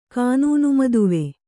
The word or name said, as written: ಕಾನೂನುಮದುವೆ